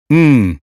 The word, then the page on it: sudden: American English